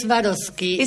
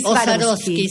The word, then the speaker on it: Swarovski: Swarovski. Swarovski. Se puede decir de las dos formas.